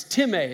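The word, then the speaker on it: timē (τιμή): And if you look at the Greek word there for honor, it's timē. It's spelled like time, but it's pronounced timē.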